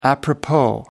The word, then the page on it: apropos: American English